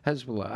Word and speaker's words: Hezbollah: Hezbollah.